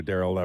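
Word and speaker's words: Daryl: Nice to also to talk to another Darrell.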